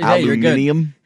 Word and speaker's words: aluminium: It says high endurance fresh deodorant, aluminum free. Old Spice. – Aluminum free. Aluminium. Yeah, you're good. – Aluminum. Yeah, well, it smells amazing. – The British say aluminium.